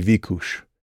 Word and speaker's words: Vicus: I picked Hungarian because Vicus said correctly would be Vikus, and that's a Hungarian pronunciation for that series of letters. It's not a Hungarian name. Vicus is entirely a fictional invention of mine, but I did model it after certain vocal styles kind of thing.